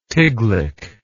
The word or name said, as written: tiglic